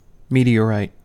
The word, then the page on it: meteorite: American English